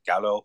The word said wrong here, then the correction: Giallo: Giallo, I think that's how Giallo, thank you.